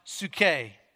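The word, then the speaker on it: psuche (ψυχή): And the Greek word that Jesus uses for life is pronounced psuche, and it means physical, emotional, psychological, and spiritual.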